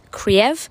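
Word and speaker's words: Kyiv: I actually looked up the correct pronunciation is Kiev. When it was Kiev, so when it was under Russian rule. So it is Kiev for anyone wondering why I'm pronouncing it like that.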